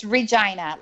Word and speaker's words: Regina: It's Regina, like the lady part. – Well, in Latin, Regina is a queen. – That's true. – As opposed to a Rex. – In Spanish, it's Regina.